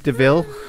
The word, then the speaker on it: Coupe de Ville: Do you like that she gets her name from a Coupe de Ville? As well, devil. – It's a coot devil. – It's pronounced Deville, mate.